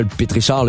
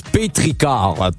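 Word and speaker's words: pétrichor: Bon, c'est pas un pétrichard, là, juste à côté, c'est marqué, ça se prononce pétricard.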